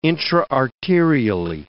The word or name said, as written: intra-arterially